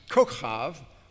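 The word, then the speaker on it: kochav (כוכב): Abraham 3:13 defines kochav, which is the correct Hebrew pronunciation, as star, and kochavim as stars, or all the great lights, which were in the firmament of heaven.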